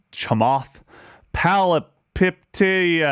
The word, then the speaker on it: Chamath Palihapitiya: And so one of the people that has actually been really interesting and outspoken is this guy named Chamath Palihapitiya.